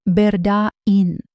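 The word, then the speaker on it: Berdaine: Locally, the name is pronounced Berda'in.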